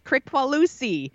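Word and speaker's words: Kricfalusi: I totally researched how to pronounce his name. It's Crickfalusi, also known as John K.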